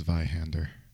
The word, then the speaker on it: Zweihander: I think it's pronounced Zweihander. – He was saying Zweilander. – I said Zweilander. – Zweilander. That's because he's thinking of Zoolander.